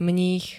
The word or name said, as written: mních